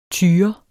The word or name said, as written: tyre